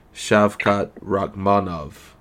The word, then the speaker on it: Shavkat Rakhmonov: The guy that just moved up three spots, Shavkat Rakhmonov. And I'm pretty sure I said his name right this time. Not Shavkat. – I think you did, but... – It's not Shavkat. It's Shavkat.